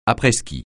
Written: après-ski

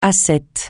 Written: ascète

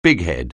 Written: bighead

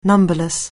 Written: numberless

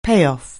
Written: payoff

